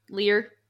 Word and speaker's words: Lear: King Lear? Am I saying that right? Lear? Lear? – Lear. – Lear? – Yeah.